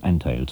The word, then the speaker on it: Antheil: Nicht nur, um Sie auf diese politische Denunzierung aufmerksam zu machen, ziehen wir es vor, die deutsche Aussprache des Namens Antheil zu benutzen, sondern auch, weil Antheils Vorfahren deutsch-polnischer Abstammung waren und weil sich die deutsche Aussprache schließlich besser einprägt.